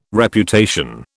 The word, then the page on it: Reputation: British English